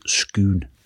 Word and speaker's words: Scone: It's written scone, but it's pronounced scone.